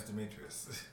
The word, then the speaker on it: Demetrius: My name is Demetrius. – Yeah. Yeah. I said it right.